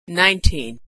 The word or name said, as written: 19